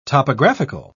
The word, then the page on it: topographical: British English